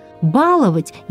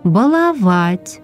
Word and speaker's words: баловать: Говорящие произносили этот глагол с ударением на первом слоге. Откроем словари. Нигде нет пометки, что вариант «баловать» является допустимым. Значит, избавляемся от неверного произношения и говорим «баловать», «балуешь», «балуется», «избалованный». Запоминаем, первый слог является ударным только в слове «баловень».